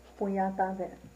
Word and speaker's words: Punyatave: How do you say that? – Punyatave. – Say it again.